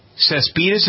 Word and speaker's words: Cespedes: So anyway, the fellow that plays for the Oakland Athletics, is it Jonas Cespedes? Is that how you pronounce his name? – Yeah.